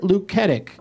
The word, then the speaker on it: Luketic: That was directed by Robert Luketic.